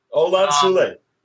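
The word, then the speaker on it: Olan Soule: I never knew how to say his name properly. Thank you. Olan Sule.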